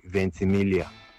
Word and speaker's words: Ventimiglia: I think the actor's name is pronounced Milo Anthony Ventimiglia.